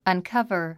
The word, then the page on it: uncover: ʌnkʌ́və r; アンカバァ